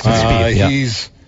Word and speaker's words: Spieth: Spieth or Spieth? – It's Spieth.